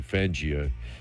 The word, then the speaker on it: Fangio: Is that how you pronounce it, Jay? Fangio, was it? Thank you, Fangio. I never really knew how to pronounce it.